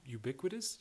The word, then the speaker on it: ubiquitous: It's pretty ubiquitous. Did I say that right? – Yeah.